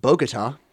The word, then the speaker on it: Bogota: It's generally pronounced Bogota. – Bogota.